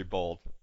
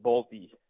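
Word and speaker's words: Bolte: So tell me a little bit about what you see out of Henry Bolt. – First, yeah, I'm in the same camp, but I just should let you know they pronounce it Bolty. – So sorry. – Like Nick Nolte, I guess.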